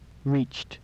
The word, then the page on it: reached: English